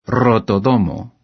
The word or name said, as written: rotodomo